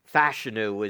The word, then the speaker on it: Fashanu: And that's Olu Fashanu, or now it sounds like it's Fashanu is the way they've been pronouncing the name.